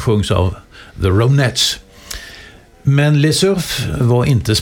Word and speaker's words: Les Surf: Då toppas de spanska topplistorna av gruppen Les Surf. Alltså det ska nog uttalas franskt för att det är, de tog sig namnet där.